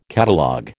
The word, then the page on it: catalogue: American English